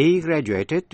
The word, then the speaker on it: graduate: He graduated from Princeton. He's a Princeton graduate. Nhớ là âm cuối trong graduate nếu là verb đọc là a, nếu là danh từ đọc là it.